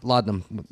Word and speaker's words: laudanum: Laudanum.